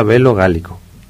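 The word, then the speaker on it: De Bello Gallico: Porque se escribe así, de Velo Gallico. Es que es latín, ¿no? No me mires así, pero se pronuncia Velo Gálico.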